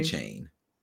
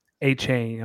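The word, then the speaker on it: Achane: I would lean into that because, like I said, A-Chain, how do you say his last name? – A-Chain. – A-Chain.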